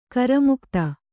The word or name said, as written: ಕರುಮಗತಿ